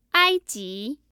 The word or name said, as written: aiji